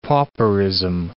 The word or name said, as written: pauperism